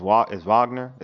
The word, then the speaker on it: Wagner: not Wagner. It's Wagner. It's like a V, Wagner, right? – Wagner. – Yeah, Mo Wagner. – Yeah. – So a player like Mo Wagner has to, like, I think this is a great opportunity because for a while, right, he wasn't getting any burn.